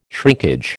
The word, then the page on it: shrinkage: American English